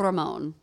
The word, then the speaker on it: Ramon: If you say Ramon, you have to say it with the rolling R. Ramon. – There you go.